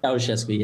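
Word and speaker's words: Ceaușescu: Ceausescu, yeah.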